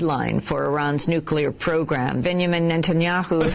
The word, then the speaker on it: Benjamin Netanyahu: I always thought it was Benjamin Netanyahu, not that, Ben blah, blah, Netanyahu. So just play this and you hear your pronunciation.